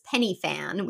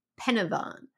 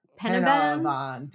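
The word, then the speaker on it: Pen y Fan: Like we were talking about going to a place that to us looks like it says Penny Fan, which is P-E-N space Y space F-A-N. But you really pronounce it Penavon. – Penavon.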